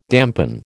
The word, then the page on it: dampen: American English